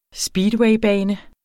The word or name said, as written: speedwaybane